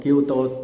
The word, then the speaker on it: Kyoto: Kyoto đúng là cái chữ nó là kinh đô. À, chứ kinh đô đọc là Kyoto.